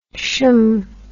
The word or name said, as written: shm